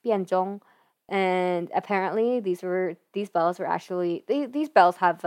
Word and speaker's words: Bianzhong: The bells that are pretty prominent in the beginning are these Chinese chime bells called Baizhong. And Bianzhong. I butchered that the first time.